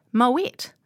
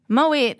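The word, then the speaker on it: Moët: Now, then she reaches for the wine and he's like, oh, Moet. And she corrects him and says, no, Moe. But actually, Moet is the correct pronunciation.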